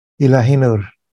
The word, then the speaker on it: Ilahinur: I downloaded to Kindle two of your other books, the Gaia Luminous, and how is that pronounced? Ilahinur? – Ilahinur. – Say that again? – Ilahinur. – Ilahinur. – Yeah, okay. Ilahi is divine, Nur is light.